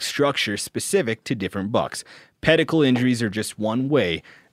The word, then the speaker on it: pedicle: The funny thing about pedicle is everybody pronounce it like medical to rhyme like medical. Because that's what Anthony Bubinick, a famous antler researcher, said, that it rhymes with medical.